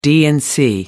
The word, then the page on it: d and c: American English